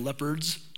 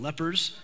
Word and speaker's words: lepers: There's a story of Jesus and the 10 lepers, not 10 leopards, 10 lepers. I think I mispronounced it a little bit last service.